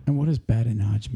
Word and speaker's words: badinage: So badinage. – Badinage. – How do you spell it? – B-A-D. – B-A-D.